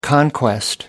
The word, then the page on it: conquest: American English